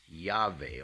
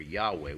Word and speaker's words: Yahweh: In the Old Testament, the name of God is Yahweh or Yahweh. We mispronounce it Yahweh because it's a German V like vine green.